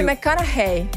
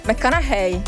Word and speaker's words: McConaughey: Matthew McConaughey. – Come? – McConaughey.